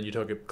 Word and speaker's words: Utopic: Uto- it's pronounced utopic, right? Utopic. Okay, I was like, I don't know how I'd butcher that.